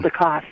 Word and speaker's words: Galdikas: Galdikas. Is that how you pronounce it? And, and... Galdikas, yes.